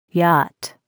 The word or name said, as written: yacht